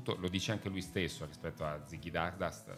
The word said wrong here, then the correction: Stardust: Stardust, scusate, continuo a pronunciarlo male,